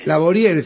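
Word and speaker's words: Laboriel: Laborier se escribe, ¿cómo se diría? Laborie, es francés.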